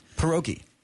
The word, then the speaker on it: pierogi: Pierogi.